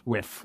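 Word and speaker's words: wif: You know, yeah, like 85% Bitcoin, 5% Slurf, 5% Zin, you know, 5% dog with hat, or, you know, whatever. – How do you pronounce that? Whiff? – Whiff. Whiff. Whiff, not with.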